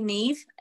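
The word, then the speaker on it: Niamh: it's actually Neve, with the MH at the end being pronounced like a V. It's Irish, yeah, like Gaelic language. That's why it's so difficult. But it's like Eve with an N. That's what I'll always say to people.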